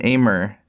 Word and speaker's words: Amer: And Amer is how you say your first name.